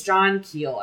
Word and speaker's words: Keel: So John Keene Keel. Sorry, it's John Keel. I've been saying it wrong this time.